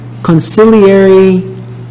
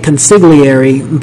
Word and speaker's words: consigliere: When Tom tells Vito that Sonny is dead, Vito refers to him as consigliere of mine. And pronounces the word phonetically. That is, he pronounces the G and the L. In Italian, in a GL blend, the G is silent.